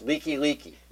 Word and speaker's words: Likelike: which means just like a stop gap, and then you just say it again. – There's this place out there that was spelled L-I-K-E, L-I-K-E. – Right. – That's leaky, leaky. not like.